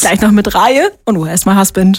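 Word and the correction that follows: Raye: Reihe, so spricht man sie aus, ne? – Scheiße, ist mir noch nicht mal aufgefallen. – Nein. – Ray, nein. – Du hast stumm vorgelesen. Ray.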